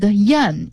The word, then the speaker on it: 燕: Swallow is pronounced yan in Chinese and an ancient name for Beijing was Yanjing.